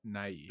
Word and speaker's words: Nighy: Nighy.